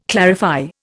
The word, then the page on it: clarify: Indian